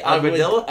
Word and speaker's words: Aguadilla: You said you're in Aguadilla? – No, say it right. Aguadilla. The L's are J's, but it's Isabella.